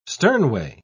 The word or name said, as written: sternway